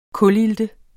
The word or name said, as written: kulilte